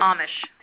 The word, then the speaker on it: Amish: And I know that the email that was sent out today was a little weird because it said, you know, our Amish or Amish, how do you say it? – Amish. – Thank you. It's Amish.